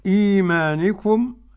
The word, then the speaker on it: إيمانكم: إيمانكم، القصر مع جميع القراء، إيمانكم إيمانكم. طيب دليل ورش يقول إيه بقى؟ وما بعد همز ساكن أو مغير فقصر، فقصر للجميع ومعهم ورش.